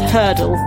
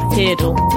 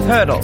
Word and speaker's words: Heardle: I've just also worked out that it's Hurdle, not Heardle, which is what I've been calling it all day. So thank you for Hurdle. – Oh. – To rhyme with Wordle.